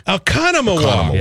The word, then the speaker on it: Oconomowoc: Oh, God. Spell it for me again. – O-C-O-N-O-M. – Oconomowoc. – Oconomowoc. – Yeah, Oconomowoc.